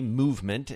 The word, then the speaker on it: MVMT: Jake Kasson, co-founder MVMT Movement is how it's pronounced.